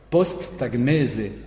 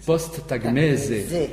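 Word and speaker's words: posttagmeze: Post-tagmese. D'accord. Post-tagmese. On a un petit peu tendance à dire z. Non, le z se prononce comme en français. Post-tagmese.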